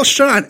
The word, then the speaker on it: Schon: Neil Sean, S-C-H-O-N.